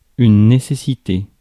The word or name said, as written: must